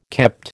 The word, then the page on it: kept: American English